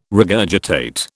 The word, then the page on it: regurgitate: British English